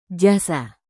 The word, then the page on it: jasa: Indonesian